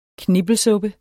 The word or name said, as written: knippelsuppe